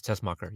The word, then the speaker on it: Tessmacher: I thought he was beside Miss Tessmacher. Did I say it right? – Yes, Tessmacher. Yep.